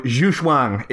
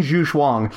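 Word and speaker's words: Zugzwang: So Zhuxuang is a German word spelled...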